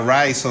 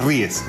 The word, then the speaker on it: Ries: Se escribe, no sé si será Rice o... pero es Ries, ¿ya?